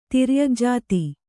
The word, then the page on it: ತಿರ್ಯಗ್ಜಾತಿ: Kannada